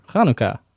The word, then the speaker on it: Hanukkah: Sure, Hanukkah, I think. – Okay, so a little bit of ha to it. – Yeah, it's a chet. The first letter is a chet.